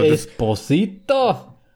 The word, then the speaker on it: Esposito: Esposito kann man schon. Also Esposito? – Das passt schon.